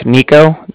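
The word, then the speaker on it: Niko: So it's my name's Nico. That's N-I-K-O. And then calling with the Bank of Cardiff. And that's spelled C-A-R-D-I-F-F as in Frank Frank.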